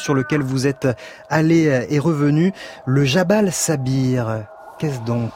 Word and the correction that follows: Sabir: Le Jabal Saber. – Saber, pardon.